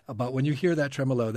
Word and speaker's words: tremolo: This is a tremolo. Am I pronouncing it? – Tremolo.